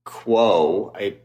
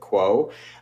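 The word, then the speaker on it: Qwo: There is now the first FDA-approved cellulite dissolving injection called Quo. It's Q-W-O, so I think that's probably how you pronounce it, Quo, which is a treatment for not like apparently like really intense cellulite.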